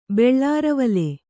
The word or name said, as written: ಬೆಳ್ಳಾರವಲೆ